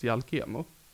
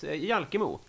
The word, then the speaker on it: Jalkemo: Jag vet inte om det är så stavas, men det uttalades Jalkemo. – Jaha, ja precis, Jalkemo.